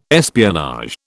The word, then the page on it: espionage: British English